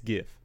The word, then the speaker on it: GIF: And then he also asked, is it pronounced GIF or JIF? And that's a stupid question. It's GIF. If anyone says JIF, you're dumb. Sorry. – JIF is the peanut butter.